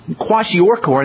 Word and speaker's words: Kwashiorkor: Kwashiorkor, and that's spelled K-W-A-S-H-I-O-R-K-O-R, Kwashiorkor, which, by the way, means, Kwashiorkor means, well, I said what it means.